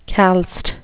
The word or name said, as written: calced